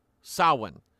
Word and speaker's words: Samhain: The M there is silent. It's pronounced Samhain.